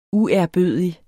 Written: uærbødig

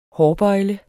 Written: hårbøjle

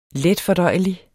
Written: letfordøjelig

